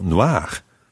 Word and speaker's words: noire: une maison noire? – Well, it sounds the same, but it's spelled N-O-I-R-E.